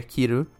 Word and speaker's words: Kiru: So for instance, right, before I even start, Akame ga Kill in the title is a play on words, because in Japanese it's Akame ga Kiru. Kiru is the English way to say kill, and also the verb to cut.